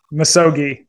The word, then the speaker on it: Misogi: Misogi. – You're close. Misogi. – Oh, I was close. Misogi. – Yeah, you were very close. Misogi. Closer than most people.